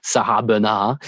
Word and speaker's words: Bernhardt: the very famous French actress Sarah Bernhardt, or Sarah Bernhardt, if you want to use the French pronunciation.